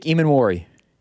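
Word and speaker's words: Emmanwori: How does he pronounce his name again? – Nick Eamon-Worry.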